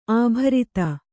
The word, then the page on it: ಅಭಿರತ: abhirata